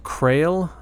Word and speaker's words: Krael: But the band is called Krael. That's how I'm pronouncing it. It's K-R-A-E-L. There's a little accent over the A.